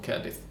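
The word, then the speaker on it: Caerdydd: Cardiff is called Cerdith and all that kind of stuff, and the double D and the double L are pronounced differently from what an English speaker would expect, but that's it.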